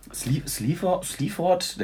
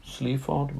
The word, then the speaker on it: Sleaford: Sleaford, das habe ich jetzt ganz schön westfälisch ausgesprochen. – Ja, Sleaford Mods.